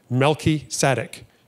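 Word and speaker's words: Melki tzaddik (מלכי צדק): And if you put the two together, melech tzaddik, Hebrew language is funny because when you jam words together, the pronunciation of the first part changes. So melech becomes melki. Melki tzaddik.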